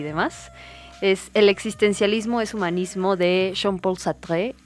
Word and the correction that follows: Sartre: Las personas que hablan francés, por favor, no me corrijan, ya sé que lo dije mal. – Ya sé que no pronuncié bien. Sartre, perdón. Pero bueno, eh, pues igual si me permites leerla.